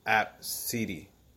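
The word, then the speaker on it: Abcde: But the mother said it's pronounced App City.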